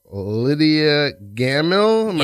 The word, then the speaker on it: Lydia Gammill: Lydia Gammill? – Yeah. Oh my gosh, you said it right.